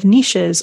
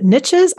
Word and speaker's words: niche: Depends which side of the Atlantic you're on. It's a niche thing over here, but a niche is I think is the American English now.